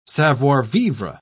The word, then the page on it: savoir-vivre: British English